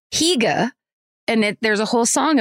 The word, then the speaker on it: hygge: But then, so thanks, Trisha, except then Sarah Tunderman wrote in and said, quick correction corner, hygge is pronounced hygge.